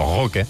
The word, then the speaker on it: Roque: Perquè Deco, que és portuguès, i Vitor Roque és brasiler, no? Es pronuncia Roque.